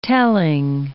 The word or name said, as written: telling